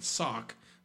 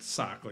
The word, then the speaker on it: Sauk: Sauk, S-A-U-K, not sock like what you put on your feet.